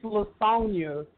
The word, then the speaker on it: Laconia: Leanne and Bianca, you pronounced my name wrong. It's Laconia.